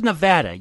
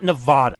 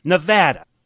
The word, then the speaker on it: Nevada: We cut to Nevada. Yes, that's how you say it, people. Not Nevada. Nevada. God, they say it like three times in this, and it's the wrong time every time.